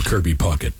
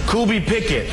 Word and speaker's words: Kirby Puckett: Well, still, the greatest ever was the top 10 mispronunciations of Kirby Puckett. – Category tonight, top 10 ways to mispronounce the name Kirby Puckett. Here we go. Number 10, Kirby Pickett.